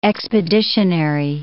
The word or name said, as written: expeditionary